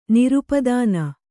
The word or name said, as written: ನಿರುಪದಾನ